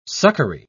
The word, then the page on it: succory: British English